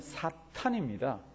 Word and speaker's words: 사탄: 사탄하고 사단은 일단 발음상의 문제입니다. 히브리 말로는 사탄입니다. 그게 우리말로 번역돼 넘어오면서 사단이라고 됐다가 지금은 아마 전부 사탄이라고 할 거예요.